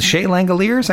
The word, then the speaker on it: Shea Langeliers: It's Shea, right? Shea Langeliers. I got that right. – Yep.